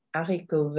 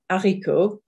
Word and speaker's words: haricot: So as you can see, les ends in S, haricot ends in TS, and vert also ends in TS. And the S on les, the TS on haricot and vert are completely silent.